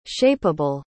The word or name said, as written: shapeable